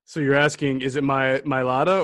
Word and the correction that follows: Mailata: So the real question is Mailata, I'm finally saying that correctly now.